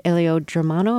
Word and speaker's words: Elio Germano: Best actor, that award was shared with between Javier Bardem and an Italian actor named Elio Germano.